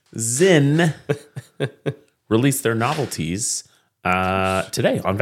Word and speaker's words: Sinn: I said recently on the show, I never know whether to say Zin or Sin, and someone was like, hey, it's someone's name, asshole. It's pronounced Zin.